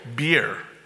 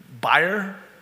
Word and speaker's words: bier: In those days, the way a funeral procession worked was the bereaved person walked in front, and right behind was a funeral bier. Buyer? Bier. I looked that one up in Google as well. It's pronounced bier officially, but I feel like I need to say buyer. Anybody with me? B-I-E-R, which I'm a little upset with the NIV for using that word.